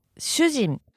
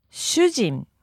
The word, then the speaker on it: 主人: 主人. – And full speed. – 主人. – You want to be careful here. It's not a long vowel.